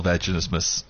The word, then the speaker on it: vaginismus: Yeah, but this couple's going through this whole vaginismus thing, and I'm sorry, did I say that right? – Yes, you did.